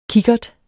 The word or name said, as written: kikkert